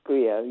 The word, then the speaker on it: Griot: Do you pronounce the T? – No, you don't pronounce T, yes. Melior Opus Griot, yes.